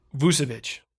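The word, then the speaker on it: Vučević: Nikola Vucevic. – That's a hell of a name. – Is that his name? I think that's how you say it. You said it right. You nailed it.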